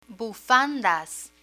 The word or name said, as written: bufandas